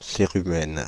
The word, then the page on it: cérumen: Paris; [se.ʁy.mɛn]; /se.ʁy.mɛn/